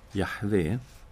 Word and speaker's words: Jahve: I det hebreiska Gamla testamentet så finns ju tetragrammet JHVH som då är det heliga gudsnamnet, som vi brukar uttala Jahve.